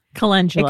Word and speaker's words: calendula: Calendula.